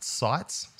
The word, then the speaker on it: CITES: I'm going to pronounce it sites. So CITES, some conservation thing about wood and things and also with you.